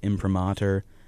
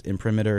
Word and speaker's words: imprimatur: So the title is technically in Latin pronounced imprimatur. But in the show, we're butchering it as imprimatur because it's easier to say, and I will too.